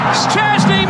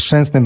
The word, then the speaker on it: Szczęsny: Szczęsny makes a brilliant!